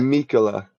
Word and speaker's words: Miquella: Mikaela.